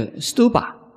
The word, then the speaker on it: stupa: 那在梵文的发音呢叫 stupa，这是做什么的？